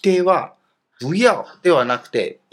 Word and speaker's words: 不要: 例えば、不要出去という時に、外行くなと、出てくなということです。発音ではですね、ヤオが第 4 世ですから、第 2 世に成長変化しますね。